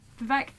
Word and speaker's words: Vivek: Also interesting, I'm sorry if I'm saying Vivek wrong. – I think we're saying it right. – Vivek Shraya.